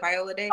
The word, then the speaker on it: Viola Davis: Is it Viola Davis? – Okay, yeah.